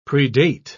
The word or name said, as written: predate